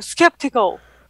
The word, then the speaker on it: skeptical: Вот, я помню, у меня девочка, которая начинала пользоваться Daniel, она мне говорит, it looks septical. Я говорю, чего? Она говорит, septical. Я говорю, еще раз говорю septical. Слово. Она говорит, я говорю, еще раз. Она говорит, ну так же Daniel произносит. Я говорю, skeptical.